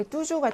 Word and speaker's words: toujours: 사실 여기 뚜주 같은 경우나, 뭐, 그렇죠. 뚜주 같은 경우처럼 이렇게 S가 있거나 없거나의 차이가 없이 그냥 발음은 똑같아요.